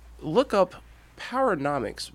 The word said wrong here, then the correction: Powernomics: by Dr. Claude Anderson. – Paranomics? – Black Power, Paranomics. Paranomics. – So Powernomics.